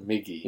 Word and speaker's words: Miggy: She said, yeah, it sounds like Mickey, but it's Miggy.